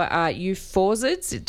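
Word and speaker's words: euphausiids: It stands for euphorzids. Did I pronounce that correctly? – Correct. It's close enough.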